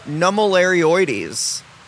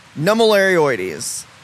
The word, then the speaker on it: nummularioides: Yes, so a Hoya that we sell is, it starts with an N, is pronounced Hoya nummularioides. Nummularioides.